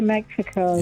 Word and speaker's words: Mexico: When you get there, don't pronounce the X the way that we do. Just say Mexico.